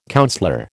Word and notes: councillor: American English

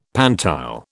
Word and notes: pantile: British English

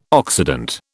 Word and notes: Occident: British English

